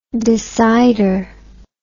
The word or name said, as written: decider